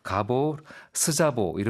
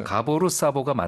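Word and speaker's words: Gábor Szabó: 그 사보, 아, 스자보는 우리식 발음이고요. 그 가보, 사보, 가보로 이렇게 얘기한다고 합니다. 반대로 얘기하기도 하고요. 이제 가보로 사보가 맞는 거죠.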